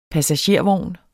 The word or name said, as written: passagervogn